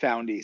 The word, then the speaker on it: Found.ee: Yeah, we call it Found.ee.